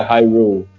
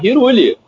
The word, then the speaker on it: Hyrule: Então na minha cabeça era, era Hiruli, era Hiruli, foda-se.